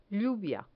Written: lluvia

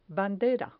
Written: bandera

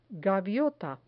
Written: gaviota

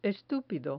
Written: estupido

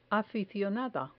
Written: aficionada

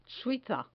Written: suiza

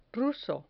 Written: ruso

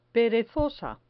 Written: perezosa

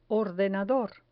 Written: ordenador

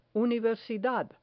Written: universidad

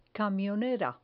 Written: camionera

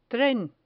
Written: tren

